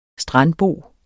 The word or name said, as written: strandbo